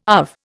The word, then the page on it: UV: Indian